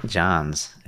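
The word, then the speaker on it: Jahns: Can we get the proper pronunciation of that? – John's. – John's. – What is it, German? – Mayor John's. All right, it's Mayor John's.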